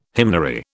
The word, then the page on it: hymnary: British English